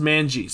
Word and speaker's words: Manji: Look up the I want you to look up the Maniji dungeon design. Tell me anything weird about it. – How do you spell it? – M-A-N-I... Oh, sorry, it's just Manji. Sorry. M-A-N-J-I.